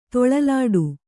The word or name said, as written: ತೊಳಲಾಡು